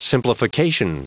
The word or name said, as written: simplification